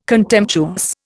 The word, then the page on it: contemptuous: Indian